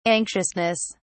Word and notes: anxiousness: British English

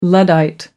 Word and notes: luddite: American English

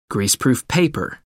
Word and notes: greaseproof paper: American English

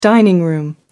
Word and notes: dining room: American English

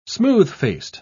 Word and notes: smooth-faced: British English

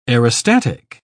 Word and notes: aerostatic: British English

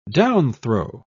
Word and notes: downthrow: British English

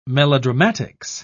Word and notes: melodramatics: British English

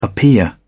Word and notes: appear: British English

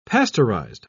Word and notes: pasteurized: British English

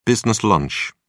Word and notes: business lunch: British English

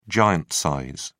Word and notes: giant size: British English

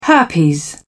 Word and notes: herpes: British English

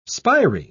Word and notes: spiry: British English

